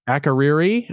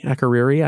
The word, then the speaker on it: Akureyri: And then you went to, and I'm going to butcher it now for you, Akureyri, Iceland? – Akureyri. – Akureyri, Iceland.